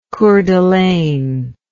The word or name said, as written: coeur d'alene